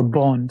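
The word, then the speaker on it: Bön: Most of Tibetan Buddhism is really Bern belief, and it's spelled B-O umlaut on O, the two dots, N, or Bon sometimes pronounced.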